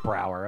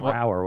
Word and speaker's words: Brower: Saying his name wrong the whole episode. I was calling him Bower. It's Brower with it. – Brower? – Like your brow.